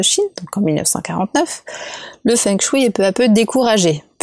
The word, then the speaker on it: feng shui: Aujourd'hui, en Asie, le feng shui, donc qu'on prononce feng shui, est entré dans le quotidien d'un grand nombre de personnes.